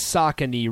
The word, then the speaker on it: Saucony: it Saucony so- I didn't I wasn't sure Sassoni. I said Sassoni uh, I don't know how to say it yeah somebody says it's Saucony not Sassani or however the hell you pronounced okay